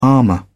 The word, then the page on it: armor: British English